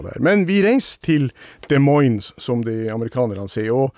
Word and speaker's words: Des Moines: ja, eh, Des Moines, kan du uttale det på fransk? – Des Moines. – Ja, men det uttales ikke sånn på fransk. Det, uttales, det, det er oppkalt etter ei elv som heter Rivière de Moine. Så det er amerikanerne som ikke klarer å uttale OI på fransk som OA, men eh, må si Moine.